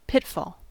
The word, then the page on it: pitfall: American English